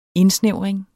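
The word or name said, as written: INDSNÆVRING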